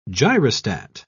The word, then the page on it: gyrostat: British English